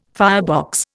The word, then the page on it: firebox: Indian